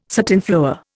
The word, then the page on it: satinflower: Indian